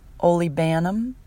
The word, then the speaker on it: olibanum: Frankincense is also called olibanum, and that may be pronounced slightly differently. You may hear that pronounced slightly differently by other people. Olibanum is how I pronounce it.